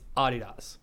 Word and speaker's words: Adidas: It's like the German name. That's how you pronounce it or whatever. And America is the only one that says Adidas. Everywhere in the world, it's Adidas.